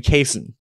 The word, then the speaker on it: Cason: But it is Cason. Someone on Twitter put it like this. Just think of Mason, but with a C.